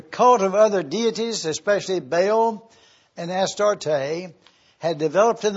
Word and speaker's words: Baal: Under the two preceding kings of Judah, Amon and Manasseh, the cult of other deities, especially Baal and Ashtaroth, had developed in the holy city. Most of us are familiar with Baal, or Baal as some pronounce it, and Ashtaroth.